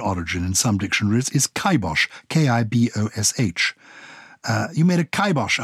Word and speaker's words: kibosh: In Gaelic language, it was a caipin bash, shortened as kibash, kai meaning cap, and bash meaning death, but pronounced kibosh, kai rhyming with I, and bosh rhyming with the German name Bosch.